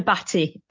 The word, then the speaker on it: Bhatia: Nearly. It's Gina Batty. – Oh, Batty. Yeah, I was thinking, yeah, I was doing more of a French twist on it, I think.